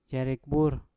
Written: yarik bur

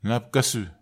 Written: napkesu

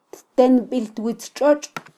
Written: ttenbittwitztxotx

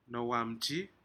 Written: nohowanamji

